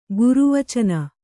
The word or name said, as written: ಗುರುವಚನ